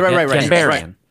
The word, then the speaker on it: Gondarian: I say it like I'm from Long Island, which that's where I'm from. So I just. – Right. – Gondarian.